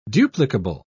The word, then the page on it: duplicable: British English